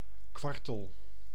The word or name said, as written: caille